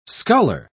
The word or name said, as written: sculler